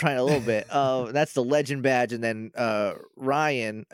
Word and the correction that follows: Raihan: It's Raihan, but I just pronounce it as Ryan in my head because that's what it looks like to me.